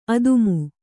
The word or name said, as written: ಆದಿಮ